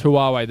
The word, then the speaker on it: Huawei: And Huawei? Is that how you say it? – I don't know. You're the expert. – Huawei. I'll say it. It's Huawei, the Chinese brand.